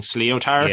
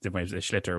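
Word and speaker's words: sliotar: Yeah, I think he was kind of pronouncing it phonetically, so it's S-L-I-O-T-A-R, just for anyone who doesn't know. So I think he was kind of saying sleotar. – Yeah, Irish doesn't, schlitter was how I would pronounce it anyway.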